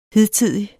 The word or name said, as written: hidtidig